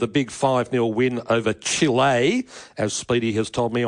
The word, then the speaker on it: Chile: Well, on that note, I went and saw some Chilean people in the crowd.